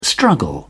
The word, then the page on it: struggle: British English